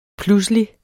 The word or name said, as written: pludselig